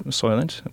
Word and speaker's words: Soylent: So we're going to make some Soylent. Is that how you say it? Make some Soylent? We'll drink Soylent? – Make some Soylent.